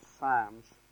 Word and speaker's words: Psalms: Go back a few pages to the Psalms or the Psalms. Depends what your background is. I call them Psalms. I don't spell it S-A-M, but it's that's how I pronounce it anyway.